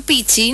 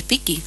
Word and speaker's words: Pecchi: Se escribe con 12, y según dicen en italiano, las 12 suena como Ki. – Sí, o sea, porque Pecchi. El doctor Pichi no es Pichi, es Piqui.